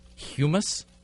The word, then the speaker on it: humus: Humus. – Humus. – Is it spelled like hummus? – Only with one M.